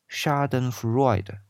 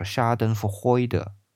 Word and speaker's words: Schadenfreude: 最后啊，在 Q 大道里边有一个词一直在提，叫做 Schadenfreude，这是英语发音啊，那德语发音叫 Schadenfreude，这个词我认为很有趣，也想聊一下。